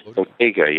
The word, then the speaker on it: bottega: Also, what I thought was interesting from this era was the role of the, I guess it's called Bottega. Bottega, is that how you pronounce it? – Bottega, yes. Yeah.